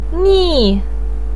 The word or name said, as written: ni4